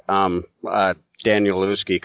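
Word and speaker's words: Danielewski: Like Daniel Lewski comes to mind if I'm pronouncing his name right.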